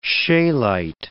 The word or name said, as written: scheelite